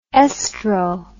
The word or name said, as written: oestro